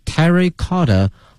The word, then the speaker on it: terracotta: 这个英文的发音呢是 terracotta warrior，前面是 terracotta T-E-R-R-A-C-O-T-T-A，后面跟的是这个 warrior，是指勇士哈，这个战士的意思 W-A-R-R-I-O-R，所以合到一起呢 terracotta warrior 翻译成中文呢就是说这个兵马俑，后面这个 exhibition 它的一个展览 open at the World Museum in Liverpool，在利物浦开展了。